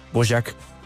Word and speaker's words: Dvořák: que aquí en Colombia lo pronunciamos Dvořák, pero es Dvořák, y lo sé porque acaba de llegar de Praga y me corrigieron, es Dvořák,